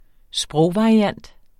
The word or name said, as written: sprogvariant